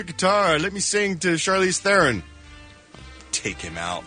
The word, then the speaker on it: Charlize Theron: Charlize Theron. That's how it's pronounced.